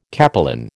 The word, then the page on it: capelin: American English